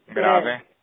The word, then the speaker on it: grave: Grave. – Yeah. – Grave. So it is pronounced like in French.